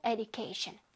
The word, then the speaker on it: education: education에서는 du 부분을 발음을 살려줄게. du. education. education. 오케이? 이 부분은 d 소리보단 주 소리를 내는 게 조금 더 좋다.